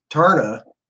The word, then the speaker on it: Taarna: I mean, even, you know, Patrick Zercher on Taarna.